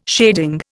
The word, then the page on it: shading: Indian